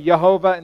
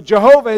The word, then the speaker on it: Jehovah: Many times, actually, the J should be pronounced as a Y, like in Spanish, right? Is that how they do it? She's nodding. I'm correct. So if you hear me say Jehovah instead of Jehovah, it's the same name, okay?